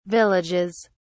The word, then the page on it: villages: British English